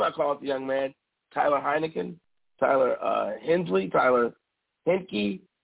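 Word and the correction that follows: Heinicke: I called him everything but his name, Taylor Henneke.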